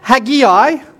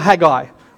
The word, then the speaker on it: Haggai: It can be pronounced Haggai or Haggai. I'm going to say Haggai because that's easier, but you can have Haggai as well.